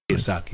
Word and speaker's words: Kiyosaki: Kiyosaki, yeah.